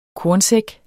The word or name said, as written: kornsæk